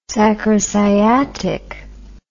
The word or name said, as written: sacrosciatic